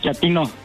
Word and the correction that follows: chatino: ¿Chapino? – No, chatino. – Es como chato, ¿verdad? Chatino. – Sí, azunero, como chato, chatino.